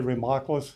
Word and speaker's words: Remaklus: My name is Larry Remakulis. If anybody needs to write that down, that's spelled R-E-M-A-K-L-U-S.